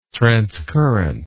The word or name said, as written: transcurrent